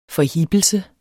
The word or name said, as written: forhippelse